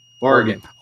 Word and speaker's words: Oregon: So first of all, we gotta coach you on how to say Oregon, okay? – Okay, let's hear it. – It's just Aura. – Or Gun. – Gun. – Oregon.